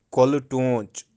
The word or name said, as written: Koli-toonch